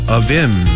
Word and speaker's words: Avvim: A-V-V-I-M, 특이한 스펠링이에요. 아윔, 올해 한번 잘 듣고 따라해보시기 바랍니다. Avim, 한번 더요.